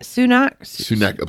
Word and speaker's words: Sunak: Sunak, I believe that's right. – Sunak.